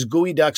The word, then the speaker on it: geoduck: like Marco said, it's spelled geoduck, uh, but it's gooey duck.